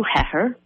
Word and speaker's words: Heher: His name is Drew Heher. It's like pepper, but with H's is how he told me to say his name.